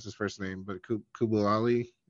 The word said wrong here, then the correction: Bilal Coulibaly: Yeah, Bilal Coulibaly.